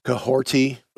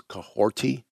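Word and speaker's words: Kahhorti: So um, Cahorty, which is how it's pronounced, uh, even though there's no T in the name, it's Cahorty, K A H dash H O R T I.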